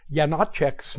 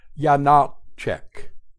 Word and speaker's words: Janáček: We will learn to pronounce it in 2 steps. Step 1, place an accent on the middle syllable, Janáček. Step 2, place an accent as well on the first syllable, Janáček, and say it quickly.